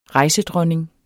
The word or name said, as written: rejsedronning